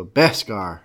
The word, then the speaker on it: Beskar: Well, make sure you say that right because Dave Mann, you know, is. – Beh. – Does not Scar. Like how he say Beh. Scar. – Beskar. He nailed us on that.